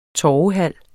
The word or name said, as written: torvehal